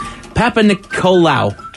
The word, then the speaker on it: Papanikolaou: It is short for the creator of the pap smear, which was Georgios Papanikolaou, or how do you say it? Papanikolaou.